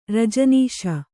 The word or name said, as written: ರಜನೀಶ